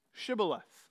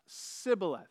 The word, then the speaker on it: Shibboleth: Okay, well then say Shibboleth. Sibboleth. No, Shibboleth. Can you pronounce it? Shibboleth. Sibboleth. See, the Gileadites knew the Ephraimites couldn't pronounce it right.